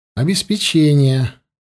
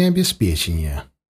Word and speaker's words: обеспечение: Я был уверен, что правильно говорить «обеспечение», а не «обеспечение».